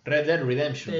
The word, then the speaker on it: Red Dead Redemption: El eh, Red Dead Redemption 2, lo simulé bastante bien, ¿o no? Sí. Red Dead Redemption.